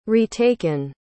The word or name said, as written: retaken